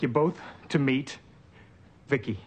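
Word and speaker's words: VICI: V-I-C-I, I pronounce it Vicki.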